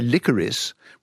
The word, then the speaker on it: licorice: And so likewise, licorice, you can say it with two or three. If you say licorice with three syllables and an S, I think people would think you're being a little bit careful.